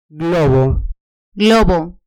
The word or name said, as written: globo